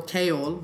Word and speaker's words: Chaol: So for example, Kaol, which I pronounced as Chaol for the past 2 months since I got these books, by the way.